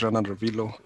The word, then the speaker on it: Ranarivelo: Like um this movie is called Born a Champion, and it's directed by Alex uh, Renarvillo. Oh God, I butchered that. Renarvillo. Forgive me, sir. I like Rana Revilo or something like that. – Yeah, Alex Rana Revilo.